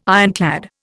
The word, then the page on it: ironclad: Indian